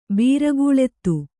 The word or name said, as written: ಬೀರಗೂಳೆತ್ತು